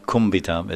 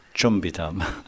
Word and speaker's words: chumbitam: It's not, by the way, it's not kumbitam, it's chumbitam. For those that don't know pronunciation.